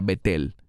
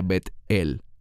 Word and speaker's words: Betel: ¿Cuántos han oído la palabra Betel? Que en hebreo se pronuncia Bet-el, y que en español se dice Betel.